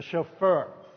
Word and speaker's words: shofar: A shofar.